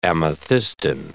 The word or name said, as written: amethystine